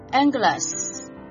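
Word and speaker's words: angelus (ἄγγελος): 他的使者，也就是说他的 his angels，他的使者就是他的天使，代码 32，我们读作 angelus，现在我们也称之为天使，意思就是使者，特别是天使或者灵体。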